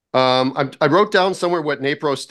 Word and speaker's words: NaPro: NAPRO? – NAPRO, yeah.